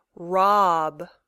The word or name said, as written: rob